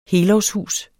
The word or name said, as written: helårshus